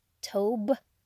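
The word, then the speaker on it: tov (טוב): Goodness, Hebrew word is tov, T-O-V is how I see it, but the V is sometimes pronounced as a B, so they might have the word tob, T-O-B. So either way, tov or tob.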